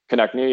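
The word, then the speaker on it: Konecny: Is that how you pronounce his name? – Travis Konechny, yeah. – Konechny, yeah.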